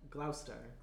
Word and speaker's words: Gloucester: How'd you pronounce it? – Gloucester. – Gloucester? Mm-hmm.